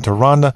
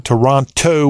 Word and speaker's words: Toronto: And boy, did the Toronto Raptors miss an opportunity to capture their first NBA championship on Monday night in Toronto. And I found out recently how to pronounce that word. It's not Toronto with a T. It's Toronto, like it got a D in it.